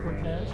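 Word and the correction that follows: Coteaz: Yeah, there's no R in there. Cotias. – Yeah, I don't know how to spell it. – I think that is Cotez. I'm pretty sure that's what it is. It's just the Cotez. Without the without the R.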